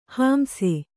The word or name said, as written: ಹಿಂಸೆ